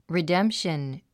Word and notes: redemption: ridém p ʃən; リデンプション

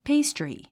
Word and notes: pastry: péistri; ペイストリィ

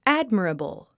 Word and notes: admirable: ǽdmərəbl; ア ドマラブル

